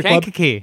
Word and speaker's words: Kankakee: So number 8 is, I'm going to mispronounce it, Kankakee Elks Country Kankakee.